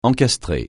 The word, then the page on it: encastrer: français